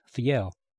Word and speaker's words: Fjell: Oh, her last name is Norwegian and is spelled F-J-E-L-L, but pronounced Fiel.